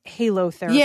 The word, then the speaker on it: halotherapy: So it's salt therapy or halotherapy. – Yeah, that's kind of how we pronounce it. Yes, because it comes from the Greek word.